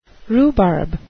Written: rhubarb

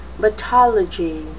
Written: battology

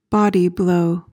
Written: body blow

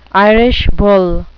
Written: Irish bull

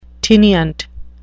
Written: tinnient

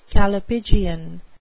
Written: callipygian

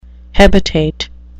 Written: hebetate

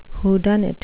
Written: whodunit

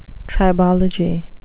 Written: tribology

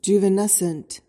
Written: juvenescent

